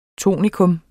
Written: tonika

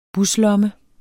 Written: buslomme

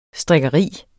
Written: strikkeri

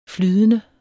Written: flydende

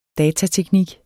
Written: datateknik

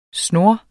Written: snur

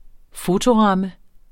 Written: fotoramme

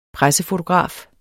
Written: pressefotograf

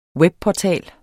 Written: webportal

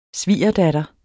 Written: svigerdatter

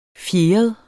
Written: fjeret

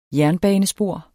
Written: jernbanespor

